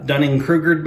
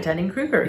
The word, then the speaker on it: Dunning-Kruger: Have I Dunning-Krugered myself? – No. – Believing that? Where'd it go? Am I saying it right? – You're right. Dunning-Kruger.